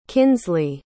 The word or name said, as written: kinsleigh